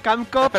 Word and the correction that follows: Capcom: Espera, repite, repite. – ¿Cómo? Ya empezamos con las coñitas. – Repite. – Canco. – Bueno. – Capcom, gilipollas. – Ahora sí.